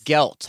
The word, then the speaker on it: gelt: Is that how you say it? Guilt? – Guilt? – You know the little coin candies? – Oh, gelt. Hanukkah gelt. – I'm saying it wrong.